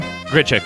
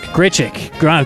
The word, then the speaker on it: Grichuk: Is that how you say his name? – Grichuk. – Grichuk. Of course.